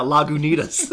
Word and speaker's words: Lagunitas: Lagunitas. – The same decision." That's exactly like, yeah, over here we pronounce that Lagunitas.